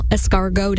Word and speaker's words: escargot: And National Escargot Day. And I think I said that correctly, so I hope you're proud of me for that one.